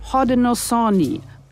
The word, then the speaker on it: Haudenosaunee: Haudenosaunee, so nennen sie sich selbst.